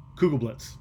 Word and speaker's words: Kugelblitz: What do they call the Kugelplex? Kugelblitz. I said it wrong again.